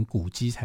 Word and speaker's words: 滑稽: 所以司马迁就讲说，呃，书离子呢，他是滑稽多智，滑稽我们好像要念成古稽才对，哈。